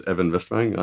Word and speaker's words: Even Westvang: And my name is Evan Westvang.